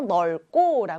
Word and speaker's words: 넓고: 그래서 널 이렇게 읽어줍니다. 그 다음 뒤에 있는 기역이 있는 이 고는요, 고가 아니라 쌍기역이 이렇게 쌍기역으로 소리가 나요. 그래서 넓고 라고 읽고요.